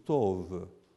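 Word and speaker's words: tov (טוב): The word good in Hebrew is tov. Sounds like T-O-V. In Hebrew it's T-O-B, but the B is pronounced V.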